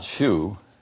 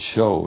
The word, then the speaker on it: shew: In fact, you have the word twice, and it's not shoe. This is just an old English spelling, S-H-E-W, of our word show. So shoe ought to be remembered, must be pronounced as show when you read the New Testament.